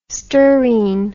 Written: sturine

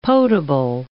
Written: potable

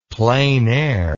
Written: plein air

